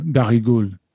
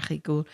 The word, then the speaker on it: Barigoule: I believe it's Barigoule, the artichoke preparation, the French. – Oh, Barigoule. Yeah. – Barigoule.